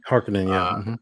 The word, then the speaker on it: Harkonnen: rivals like Baron Vladimir Harkonnen, I think that's how you pronounce his name. – Harkonnen, yeah.